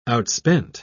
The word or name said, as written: outspent